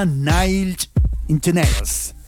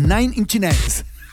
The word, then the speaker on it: Nine Inch Nails: Viernes Libre y suena Nine Inch Nails. Lo dije mal, ¿eh? Porque es Nine Inch Nails.